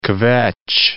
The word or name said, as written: kvetch